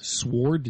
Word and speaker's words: sword: Sword? Did you just say sword? – Sword. – He did say it, didn't he? – Yeah. – I pronounced the W a little too much there.